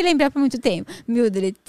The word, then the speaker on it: Mildred: Mildred.